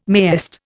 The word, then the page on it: mayest: Indian